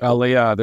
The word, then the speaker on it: Aliyah: Do you call it Aliyah? Is that how you pronounce it? – Aliyah.